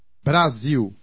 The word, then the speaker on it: Brasil: A final L or an L before a consonant are pronounced like a W or like the U of soon. – Brasil.